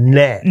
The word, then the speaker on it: gnat: Now, is it of gnat or is it gnat? – No, it's gnat. The G is silent.